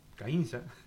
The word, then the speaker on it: Caínza: Fueron 35 milímetros en ¿cómo se dirá? Caínza, Artigas, Caínza, Artigas, ¿cómo dice usted? A ver acá. – Sí, Caínza. – ¿No? – Perfecto. – Caínza, no es porque no lleva tilde nada, Caínza.